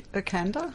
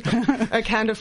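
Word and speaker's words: Irkanda: And we're also doing this Sculthorpe, I can't even pronounce it. I think it's Okanda. – Thank you. Thank you. Yes. Thank you. Okanda 4.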